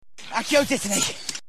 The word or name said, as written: accio dittany